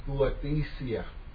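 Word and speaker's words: huathesia (υἱοθεσία): The number will be 5206, and the word is going to be pronounced huathesia. Huathesia.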